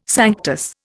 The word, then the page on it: sanctus: Indian